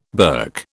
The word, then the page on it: berk: British English